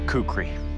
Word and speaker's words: kukri: I believe it's a Kukri knife. – The Kukri.